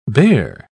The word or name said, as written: bare